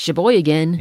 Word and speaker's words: Sheboygan: So it's Sheboygan.